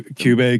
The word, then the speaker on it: Kyubey: And speaking of another cat-like creature in the more recent Magical Girl, Kyubey. I believe that's how it's pronounced in Pula Magic. – Yeah, Kyubey, QB.